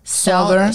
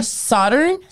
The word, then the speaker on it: Southern: How do you pronounce that? Southern. Southern.